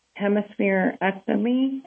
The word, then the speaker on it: hemispherectomy: hemispherectomy. I don't know if I'm saying that correctly. – Yeah, that's perfect, yeah.